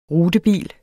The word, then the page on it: rutebil: [ ˈʁudəˌbiˀl ]; [ ˈʁuːdəˌbiˀl ]